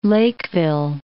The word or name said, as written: Lakeville